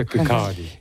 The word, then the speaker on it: Kirkcaldy: Se pronuncia Kirkcaldy.